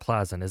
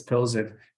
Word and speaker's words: Pilsen: I've been calling them Victoria Plazen, and I realize it's not Plazen, is it? – I think it's Pilsen.